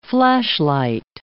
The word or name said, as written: flashlight